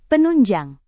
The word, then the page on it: penunjang: Indonesian